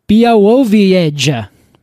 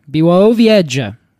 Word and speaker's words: Białowieża: Białowieża. That's how I pronounce it. That's how I believe it's pronounced. – Did you find a pronunciation guide for this? – Yeah, okay. Białowieża. And edge is all capitalized, so I think that's where the emphasis should go.